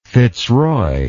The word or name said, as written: fitzroy